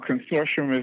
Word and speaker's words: Consortium: But I feel like with you and Antipop Consortium, if I'm pronouncing it correctly, I've heard it pronounced many different ways. – Yeah, no, Consortium is the correct pronunciation. Yeah, that's it. – Right.